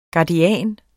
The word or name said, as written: guardian